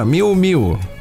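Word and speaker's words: Miu Miu: А, компания Миу Миу, это хороший бренд, не знаете? Миу Миу? Да, Миу Миу.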